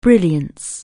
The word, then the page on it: brightness: anglais